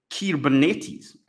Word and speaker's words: Kubernetes: With orchestration, typically this component is an off-the-shelf management such as Kubernetes.